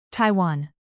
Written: Taiwan

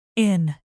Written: inn

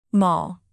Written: mall

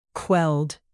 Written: quelled

